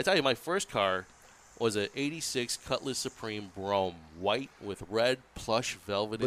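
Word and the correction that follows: Brougham: But you really need to learn how to pronounce it. It's called a Broham.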